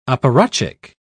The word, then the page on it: apparatchik: British English